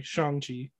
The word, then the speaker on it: Shang-Chi: Or I'm sorry, Shang-Chi. That's how you pronounce it.